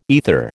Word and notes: aether: American English

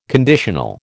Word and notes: conditional: American English

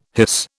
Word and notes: hiss: British English

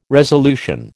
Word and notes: resolution: American English

